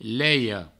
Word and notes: leia: provençal; leio

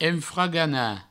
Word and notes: enfraganar: enfragana

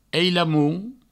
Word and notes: ailamont: eilamount

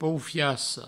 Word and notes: poufiassa: poufiasso; provençal

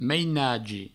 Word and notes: mainagi: meinagi

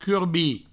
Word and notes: curbir: curbi

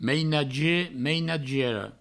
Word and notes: mainagier: mainagiera; meinagie; meinagiero

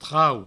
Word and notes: trauc: trau

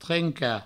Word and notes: trencar: trenca

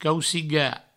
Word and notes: caucigar: provençal; cauciga

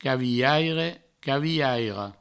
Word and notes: cavilhaire: provençal; cavilhaira; cavihaire; cavihairo

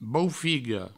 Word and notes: boufiga: boufigo

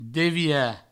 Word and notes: devirar: Provençal; devira

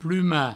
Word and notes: plumar: pluma

